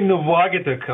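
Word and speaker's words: Nuvvuagittuq: I was trying my best to nail the pronunciation of this Greenbelt area, Nuvwagadik? – Yeah, I would say Nuvwagadik. Of course, it's an Inuktitut name, so my pronunciation is probably not okay either.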